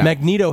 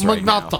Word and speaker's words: Magneto: Magneto is more of the Italian pronunciation, and Magneto is more of the douchebag pronunciation.